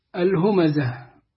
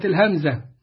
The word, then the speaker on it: الهمزة: سورة الهمزة، وهكذا تنطق. أما الذين لا يعرفون النطق الصحيح يقرؤونها سورة الهمزة. تقول له يا ابني الهمزة، يقول لك ما هو يا أستاذ ما هي مش متشكلة، مش مكتوب كده بضم الهاء وفتح الميم.